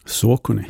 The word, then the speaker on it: Saucony: Kan du uttala namnet? – Saucony. – Ja, det satt den.